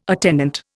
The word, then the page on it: attendant: Indian